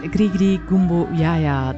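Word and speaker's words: Gris-Gris Gumbo Ya Ya: Je hoorde Dr. John met Grigri Gumbo Yaya. Dat, de titel van het liedje wil ik blijven uitspreken totdat ik het correct heb. Ik krijg een thumbs-up van Wim, dus ik zou het wel goed hebben.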